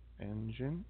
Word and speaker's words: Enjin: Engine. And it's spelled ENJIN. Okay.